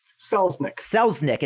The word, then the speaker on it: Selznick: So, my guest today is Matt, I'm sorry, I should have asked this before, but how do you pronounce your last name? – Selznick. – It's exactly how it's spelled.